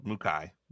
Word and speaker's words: McKay: It's exactly how it's spelled. McKay.